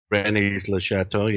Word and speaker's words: Rennes-le-Château: Rennes-le-Château, yeah.